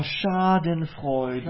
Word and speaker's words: Schadenfreude: Find out what kind of a hindrance is Schadenfreude. I don't know if I'm pronouncing it. – Is what? – Schadenfreude is a German word. – Oh, Schadenfreude. – Schadenfreude. – Oh, Schadenfreude. Oh, that's an interesting one.